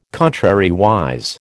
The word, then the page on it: contrariwise: American English